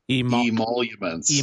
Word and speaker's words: emoluments: Emoluments.